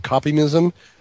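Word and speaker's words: Kopimism: It means copy me. Copymism. It's copy me is what it means.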